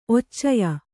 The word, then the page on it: ಒಚ್ಚೆಯ: occeya